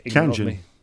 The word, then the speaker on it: Tianjin: It's Tianjin.